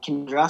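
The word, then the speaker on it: Canowindra: It's pronounced Canawindra. It's spelt Canawindra, but it's pronounced Kindra.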